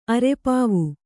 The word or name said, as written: ಅರೆಪಾವು